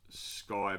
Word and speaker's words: Ckye: And it's pronounced Sky, but it's like C-Y-K, something weird. – C-K-Y-E.